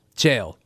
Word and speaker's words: Chael: That is not the correct way to say it. – Yeah, Chael. Interesting. – One syllable. Chael.